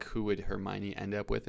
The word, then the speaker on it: Hermione: So the first topic for today is related to Harry Potter and Hermione.